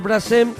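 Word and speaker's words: Brassens: Ya nos vamos a una versión que también hizo muy popular eh, Loquillo de un tema de George Brasen y lo he dicho bien. Sí, sí, perfecto.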